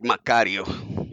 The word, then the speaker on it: Macario: Am I saying that correctly? – That is correct, Macario.